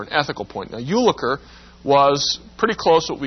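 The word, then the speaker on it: Jülicher: Early in the 20th century, there's a man named Ulliker. By the way, it's spelled J-U-L-I-C-H-E-R.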